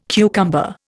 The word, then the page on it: cucumber: Indian